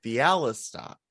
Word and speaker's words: Bialystok: Which is probably why Leo Bloom was so easily seduced by our Bialystok. Yeah, God damn it, I know how to pronounce this name. Bialystok.